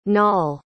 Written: naal

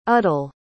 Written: udell

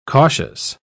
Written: cautious

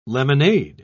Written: lemonade

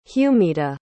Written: humita

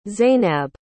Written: zaynab

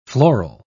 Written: floral

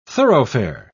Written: thoroughfare

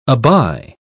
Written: aby

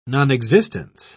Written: nonexistence

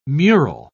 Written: mural

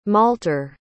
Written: malter

